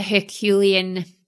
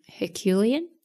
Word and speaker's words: Herculean: Herculean? – Herculean. It's Herculean.